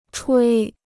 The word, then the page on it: 吹: chuī